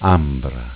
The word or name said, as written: Ambra